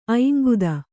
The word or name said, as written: ಐಂಗುದ